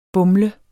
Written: BUMLEDES

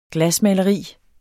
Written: glasmaleri

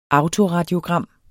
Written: autoradiogram

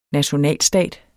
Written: nationalstat

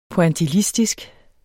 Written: pointillistisk

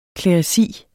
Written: kleresi

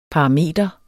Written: parameter

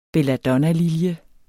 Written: belladonna

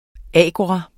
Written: AGORAEN

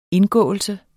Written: indgåelse

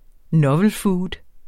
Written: novel food

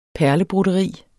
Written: perlebroderi

